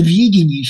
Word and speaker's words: видение: Видение, еврейский текст, и текст греческий, и текст латинский подчеркивает, я имею в виду, да, вот переводы этого еврейского слова, что речь идет именно о видении с ударением на первый слог.